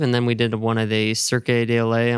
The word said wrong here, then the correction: Cirque du Soleil: I'm going to bastardize that pronunciation. – Cirque du Soleil. Yeah.